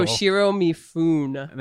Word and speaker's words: Toshiro Mifune: Toshiro Mifune.